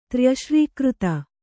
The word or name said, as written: ತ್ರ್ಯಶ್ರೀಕೃತ